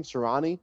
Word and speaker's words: Sirianni: But let's get into the coaches because I know you want to talk about the Eagles signing of a Nick uh, Sirianni. Is that how you say his name? Sirianni.